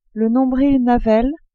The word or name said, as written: nombril